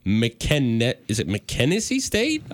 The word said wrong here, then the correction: McNeese: McNeese. – McNeese, I knew I was going to butcher that. McNeese State.